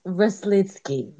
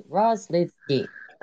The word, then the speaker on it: Roslycky: It's Roslycky. Like Ros lit a cigarette and went to go ski. Roslycky.